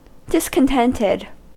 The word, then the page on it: discontented: American English